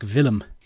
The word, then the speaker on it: Willem: Although I'm pretty sure they pronounced it more like Willem.